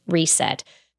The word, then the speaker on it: RSET: The new medium is called RSET, pronounced reset. Should be easy for everyone to remember because it's teaser, spelled backwards.